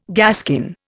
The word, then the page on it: gaskin: Indian